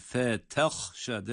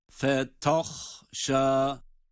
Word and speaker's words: فَتَخْشَى: Bunu ya kolayımıza gelmesi ya da işte ha'dan etkilenmesi sebebiyle asla fetahşa fetahşa şeklinde kalın okumuyoruz ta harfini.